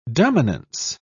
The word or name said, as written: dominance